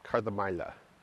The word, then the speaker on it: Kardamyla: I've been corrected by the Greeks on how to pronounce it, Kardamyla.